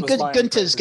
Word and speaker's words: Gunther: Gunter, it's pronounced Gunter.